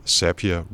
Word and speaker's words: Zapier: Der er, jeg tror faktisk, det hedder Zapier, fordi jeg har hørt, at Zapier rhymes with happier. – Okay, ja. men lige meget, men Z-A-P-I-E-R,